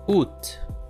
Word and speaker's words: août: I think this one is pretty easy, but if you look how the word is spelled, maybe we'll get a little confused, so focus on the pronunciation of this word. Once again, août.